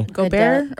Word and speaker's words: Gobert: What's his last name? Because I hate trying to pronounce it because I always get it wrong. – Gobert. – Rudy Gobert. Yeah. That's how you say it.